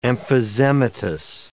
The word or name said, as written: emphysematous